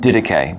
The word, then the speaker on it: Didache: Didache. – Didache. Didache 8:2. What is the 8:2 like chapters and verses like yeah. – in the Bible? – Yeah. – Sorry. – Yep. The Didache,